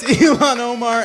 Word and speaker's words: Ilhan Omar: Ely Omar. How do I say that? – It's Ilhan Omar and Jesse Waters.